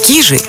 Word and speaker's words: Кижи: В Заонежье традиционно используется ударение на первый слог – «кижи». Однако в современности более распространено название с ударением на второй слог, которое филологами признано общепринятым.